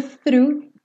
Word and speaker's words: through: Lembrando que para fazer o through, o GH ali do final você pode fingir que não existe, porque você não vai ter que pronunciar. E o through tem que começar aqui com o th, tá?